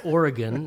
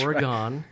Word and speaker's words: Oregon: I know it's pronounced Oregon, not Oregon.